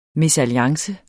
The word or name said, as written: mesalliance